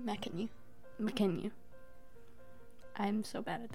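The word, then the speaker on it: Mackenyu: Okay, the kid actor for Zoro is literally the spin image of Makenyu. Did I say that right? – Makenyu, yeah.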